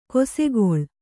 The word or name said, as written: ಕಿಸುಗುಳಿ